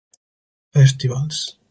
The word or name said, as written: festivals